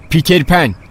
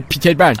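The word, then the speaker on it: Peter Pan: وقتی میخوای بگی پیتر پن باید پهاشو درست تلفظ کنی، بگو تا دهنت عادت کنه. – چطوری؟ – په پیتر پن، تکرار کن ببینم.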